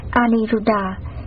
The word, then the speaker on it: Aniruddha: Ấn Độ đọc là Aniruddha.